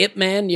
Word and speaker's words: Ip Man: Well, like Ip Man. You ever see Ip Man? No. It's IP Man, but it's pronounced Ip, Master Ip.